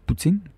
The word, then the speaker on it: poutine: Les Américains, comment ils prononcent, eux autres, Poutine? – Poutine. – Poutine, hein? – Oui, Poutine, c'est euh, comme. c'est Puis parfois, je vais l'avoir, je vais le voir inscrit P-O-U-T-E-E-N, comme teenager, teen, Poutine.